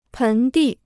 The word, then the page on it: 盆地: pén dì